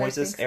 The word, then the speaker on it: Moises: Moises Arias.